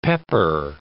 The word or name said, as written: pepper